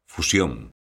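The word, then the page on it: fusión: Galician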